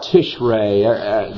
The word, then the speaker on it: Tishrei: Now, from ancient times, there was a year that began with, well, I'll call it Tishrei. That's approximately the pronunciation.